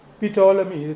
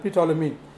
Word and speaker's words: Ptolemy: Okay, now the ruler at that time of Alexandria was, it's all a big history, was Ptolemy. Okay, Ptolemy, P is silent, Ptolemy.